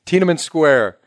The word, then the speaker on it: Tiananmen Square: And you got to tell your Tiananmen's, am I pronouncing this right? – Yeah. – Tiananmen Square. – Yeah.